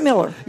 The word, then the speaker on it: Moeller: This one's by Gary James Moeller. – Miller. – Is it Miller? – Yeah. M-O-E-L-L-E-R. Digitally recorded like that, but you pronounce it Miller.